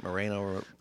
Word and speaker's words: Moreno: Moreno it rhymes with Pequeño.